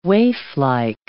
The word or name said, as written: waiflike